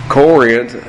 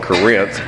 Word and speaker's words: Corinth: Corinth, which is the way I pronounce it. If you live north of here, you call it Corinth, but we'll call it Corinth just for that sake.